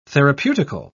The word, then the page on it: therapeutical: British English